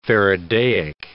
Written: faradaic

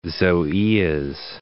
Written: zoeas

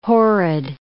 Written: horrid

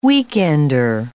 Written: weekender

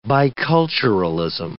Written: biculturalism

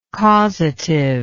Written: causativity

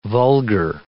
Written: vulgar

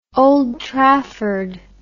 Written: Old Trafford

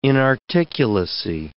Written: inarticulacy

